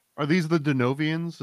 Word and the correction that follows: Denisovans: Is that how you pronounce that? – The Denisovans, I believe.